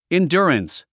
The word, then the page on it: endurance: indjúərəns; インデュアランス